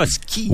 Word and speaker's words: Huskies: Parce qu'il faut dire Huskies. – Oui, les Huskies. – Parce qu'en français, on prononce pas le S au pluriel.